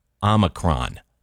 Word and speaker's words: Omicron: And in classical Greek, it was pronounced with the second syllable sounding like me. – Well, that's interesting. Now, I know linguistically. – Omicron.